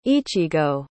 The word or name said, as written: ichigo